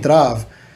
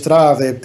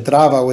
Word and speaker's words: Trav: I'm saying Trav. So I don't know why they released it like that. – Wow. – It's, you know, instead of just Trav, they pronounced it Trava with a T-R-A-V-A.